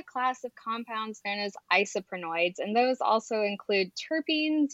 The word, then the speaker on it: isoprenoids: So you previously mentioned that you study isoprenoids. Did I say that right? – Yeah, that's right. That's perfect.